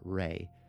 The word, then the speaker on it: Ruey: So, for clarification, the last name R-U-E-Y can be pronounced, according to the pronunciation guide, as Ruey or Ray.